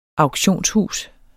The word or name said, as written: auktionshus